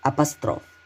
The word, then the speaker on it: апостроф: Номер девять, Апостроф. Правильно ставить ударение только на последний слог.